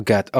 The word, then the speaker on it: gato: Oh, how do you say cat in Spanish? – Gatto. – Oh, gato. Okay.